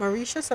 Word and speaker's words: Mauritius: M-A-U-R-I-T-I-U-S. Is it Mauritius?